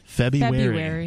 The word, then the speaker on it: February: My favorite pronunciation is February. – February? – February. Yeah, like you're wary of Febby.